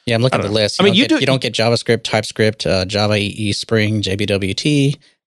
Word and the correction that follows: JWT: Did I say that right? JWT. JWT.